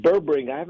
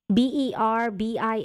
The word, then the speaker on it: berberine: Berberine. I'm not too familiar with that. – Okay. – How do you spell that? – Berbine, I believe.